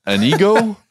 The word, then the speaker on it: Antigo: Apparently the T is silent, which I've never heard of before. So it's Anigo? Anigo? I don't know, something like that, but no T in there, even though it looks like there's one.